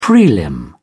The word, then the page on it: prelim: British English